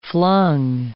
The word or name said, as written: flung